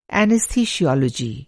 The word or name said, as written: एनिस्थिसियोलॉजी